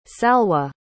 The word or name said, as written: salwah